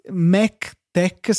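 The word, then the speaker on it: MacTeX: Mac, Mac Tech, scritto Mac Tex, è una distribuzione di LaTeX per Mac, va benissimo, e TechPad, scritto TechPad, anche questo, la X si legge sempre K quando si parla di LaTeX, ehm, è il nostro editor preferito in assoluto su tutte le piattaforme.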